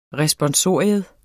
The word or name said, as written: RESPONSORIUM